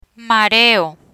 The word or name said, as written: mareo